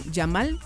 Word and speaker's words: Yamal: Tiene 19 años, si no estoy mal, es el más jovencito, creo que con Yamal, decime si lo dije bien.